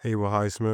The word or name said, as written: Haywood Highsmith